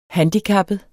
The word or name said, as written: handicappet